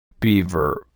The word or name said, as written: beaver